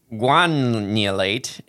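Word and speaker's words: guanylate: It's gum. It's like gum. – Oh, okay, gunulate. – I know it's not gunulate. Spelled like that. – Yeah, but like Guam. – Yes, guanulate. Like guanulate. Yeah.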